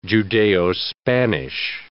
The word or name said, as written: Judeo-Spanish